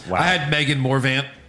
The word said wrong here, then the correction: Morant: Am I saying it wrong? – Yeah. – What? – There's no V. – Megan Morant. – Oh, Morant, yeah.